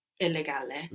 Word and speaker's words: illegale: Illegale. – Very good. – Illegale. – And you pronounce both L's as well.